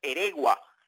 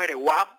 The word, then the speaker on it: Eregua: como es Greifer Eregua. En principio decíamos Eregua, pero nos aclararon que la pronunciación correcta es Eregua y que pertenece a los Cachorros de Chicago.